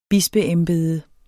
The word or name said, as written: bispeembede